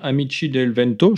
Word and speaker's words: Amici del Vento: Et on a l'exemple d'un concert en décembre 78 qui, où un groupe italien, Amici del Vento, je ne prononce pas avec le, bon accent Non, c'est très bien. très, très bien.